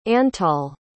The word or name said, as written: antall